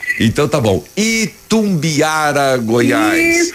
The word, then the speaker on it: Itumbiara: Itumbiara. Falei certo a palavra? – Agora você falou certo, Itumbiara, Goiás. – Que às vezes eu falo Itumbiara, né? – É na...